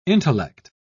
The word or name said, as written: intellect